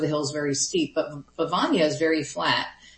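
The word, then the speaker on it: Bevagna: I want to know about the birds of Bivagna. Did I say this correctly? – Oh, yes, you did. Well pronounced. I appreciate that. Yeah, Bivagna is beautiful.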